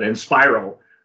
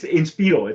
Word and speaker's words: Inspiro: This is a one called Inspiro, or some people are going to mispronounce it and call it Inspiro, but it's Inspiro. It's the Spanish pronunciation.